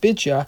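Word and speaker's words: Bitcha (בתיה): It's typically pronounced Bacha, but in the Tanakh it's pronounced Bitcha.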